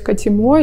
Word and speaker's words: Катимо: Я говорю, не, Катимо, это какой-то бред, что такое Катимо. А потом она говорит, ну или переставьте, может быть, ударение. И получилось, получилось Катимо, и мне оно так как-то созвучно стало.